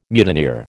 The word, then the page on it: mutineer: American English